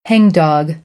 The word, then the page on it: hangdog: American English; ɑː - ɒ